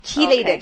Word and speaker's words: chelate: Yeah, chelated. Okay. Or chelated or chelate iron, it's spelt C-H-E-L-A-T-E, iron.